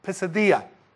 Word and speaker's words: Pisidia: But this is in the region of Pisidia, is how you say that.